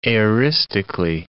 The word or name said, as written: aoristically